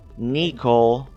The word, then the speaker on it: Necole: prior to the eighth victim, a prostitute warned the task force that Nicole, and her name is spelled N-E-Cole, like N-E-C-O-L-E, Nicole, somebody said Nicole would be the next victim.